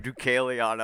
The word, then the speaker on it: Deucalion: Deucalion. Yeah, you crushed the pronunciation.